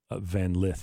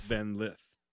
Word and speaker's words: Van Lith: Her and how'd you say her name? Lift, not Van Lith. – Van Lith.